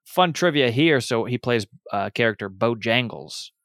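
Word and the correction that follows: Bonejangles: Well, no, I said that wrong. Let me. Bone Jangles.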